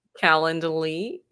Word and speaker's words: Calendly: How do you say Calendly? Saying that right? – Calendly, yes.